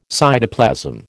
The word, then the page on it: cytoplasm: American English